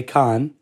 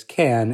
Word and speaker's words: Cannes: All right, well, if you're French, you would say Cannes. But the rest of the industry outside of France says can, as in a can of tomato soup.